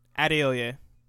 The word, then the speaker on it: Adeoye: Adeoye.